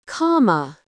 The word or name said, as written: comma